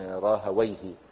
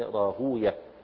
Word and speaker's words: راهويه: طريقة النحاة يعربونها فيقولون راهويه، وطريقة المحدثين ينطقونها كنطق الفرس لها يقولون راهوية، يعني زي سيبويه وسيبوية ومردويه مردوية وهكذا.